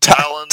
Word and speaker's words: Taillon: Taillon.